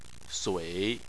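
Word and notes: sui: tone 3

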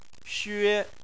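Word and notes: xue: tone 1

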